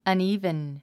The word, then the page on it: uneven: ʌ̀níːvən; アニィーブン